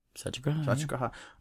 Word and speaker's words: Satyagraha: You play like Satyagraha. – Mm-hmm. – Did I say that right? – Satyagraha.